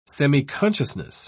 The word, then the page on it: semiconsciousness: British English